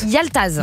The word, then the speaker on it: Yaltaz: Comment ça se prononce? – Yaltaz. – Yaltaz, c'est joli. – C'est comme ça, hein, Yaltaz.